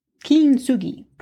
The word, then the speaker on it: kintsugi: Kintsugi. Did I say it right? Kintsugi. That's the way it's said.